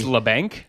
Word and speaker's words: Labanc: Kevin Labanc factor now that we know how to say his name correctly. – It's Labanc. – It's Labanc. At least that's how I heard them say it on the broadcast.